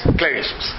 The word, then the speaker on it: Kulevicius: Culevicius.